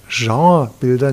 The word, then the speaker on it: Genrebilder: Die Bilder von Vermeer, die wir heute eben Genrebilder nennen, so französisch ausgesprochen, diese Bilder führen uns in das, ich sage mal, ordentliche, wohlhabende Bürgertum.